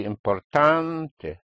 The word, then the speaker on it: importante: Important, therefore, will be importante. So ante and ente. Ante and ente. With the stress on the an or en.